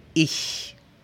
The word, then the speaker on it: ich: Pero cuando hay una E o una I antes, la pronunciación cambia. Por ejemplo, yo se dice ich, ICH, ich. Y este es un sonido que tú no conoces del español.